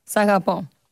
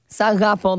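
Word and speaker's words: Sagapo (σ' αγαπώ): Sagapo. – Say what? – Sagapo. – Sarapo. – Sagapo. Like Sagapo.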